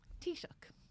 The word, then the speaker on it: Taoiseach: How do you say the name of what is your head of government? – Taoiseach. – Taoiseach.